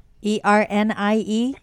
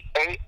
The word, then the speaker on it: Ernie: ErnieG. – G. – Actually, people can spell it wrong. I get people calling me Earnie. I'm like Ernie. Oh, like if I'm earning my name. No. – Yes, yes. I'm earning a living. – Earning a living. Ernie. E-R-N.